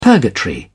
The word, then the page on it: purgatory: British English